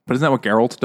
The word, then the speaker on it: Geralt: It's a very hard G. – Geralt.